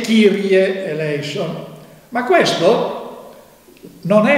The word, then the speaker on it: Kyrie: Noi adesso nelle nuove messe dobbiamo invece dire Kyrie eleison. Kyrie è la pronuncia del VI-VII secolo dopo Cristo,